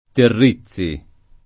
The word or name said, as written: Terrizzi